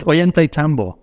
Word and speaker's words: Ollantaytambo: How do you say it? Ollantaytambo? – It's Ollantaytambo. – Okay, cool. – So, yeah, Vince, I thought I'd get that wrong. – Look, everyone gets it wrong, to be honest, Vince.